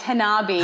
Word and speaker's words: Hanabi: I think that's how you say it. Hanabi. – Oh, it's Hanabi. Hanabi.